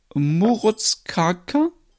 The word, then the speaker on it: Morotskaka: Murutskaka? ja, so, so, so ähnlich wird er ausgesprochen.